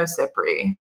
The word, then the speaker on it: Cipri: And My name is Nino Cipri. – Okay, it is. – It is an anglicized Italian last name.